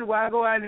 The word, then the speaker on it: Wahgwaan: Wagwan Entertainment. Did I say it right? – Well, you did good. Wagwan Entertainment.